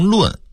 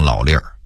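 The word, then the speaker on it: 老论儿: 北京话里有个词儿老粒儿，这个粒儿啊您要读成论可就露怯了，但写得写成论文的论。北京话一定要读成老粒儿，意思是老年间流传下来的说法和规矩，但它实际上是变音字。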